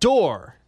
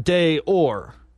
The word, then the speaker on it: d'Or: The D'Or is is pronounced door, not day or.